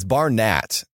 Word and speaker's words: Barnett: I've always said Barnett, but it's spelled with an A. Is it Barnett? – It's Barnett.